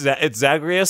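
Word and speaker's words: Zagreus: It's Zagarias, so I love that you remembered the wrong pronunciation.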